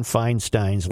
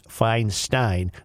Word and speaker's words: Feinstein: Senator Dianne Feinstein's last name is pronounced Feinstein, not Feinstein. – Oh. – In the German language, EI is pronounced EYE, and IE is pronounced EE.